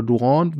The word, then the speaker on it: Durant: Ich erwähnte ja schon Kevin Durant oder Durant, wie er ausgesprochen wird, als Peter.